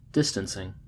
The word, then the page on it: distancing: American English